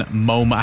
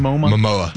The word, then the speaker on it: Momoa: I'm going to cut Jason Momoa. How do you say his last name? – Momoa. – Momoa, that's right.